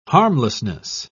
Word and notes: harmlessness: British English

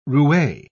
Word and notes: roue: British English

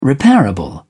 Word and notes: repairable: British English

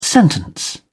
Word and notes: sentence: British English

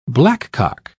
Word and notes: blackcock: British English

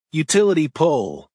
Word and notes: utility pole: American English

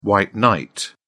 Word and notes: white knight: British English

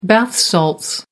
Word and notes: bath salts: American English

